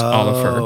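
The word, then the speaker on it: Ólafur: So it's O-L-A-F-U-R. I believe it's pronounced similar to the name Oliver, but it's Oliver.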